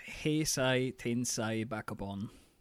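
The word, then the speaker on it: Heisei Tensai Bakabon: Am I saying that right? See, I'm demonstrating my inability to speak Japanese right now. You're welcome, folks. – I will save you. It's Heisai Tensai Bakubon.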